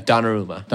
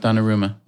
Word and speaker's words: Donnarumma: Donnarumma. – Donnarumma.